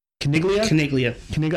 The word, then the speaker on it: Caniglia: Caniglia. I keep saying Caliglia because we had the Rome episode, the Praetorian Guard episode.